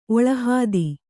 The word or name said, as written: ಒಳಹಾದಿ